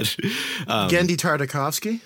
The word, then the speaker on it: Genndy Tartakovsky: I can't remember the director's name or at least how to pronounce it, but Genndy Tartakovsky.